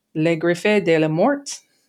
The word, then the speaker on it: Les Griffes de la Mort: It did have another re-release in 1988 by Columbus Video as The Claws of Death or Les Griffes de la Mort. I think I pronounced that correctly. – It's good.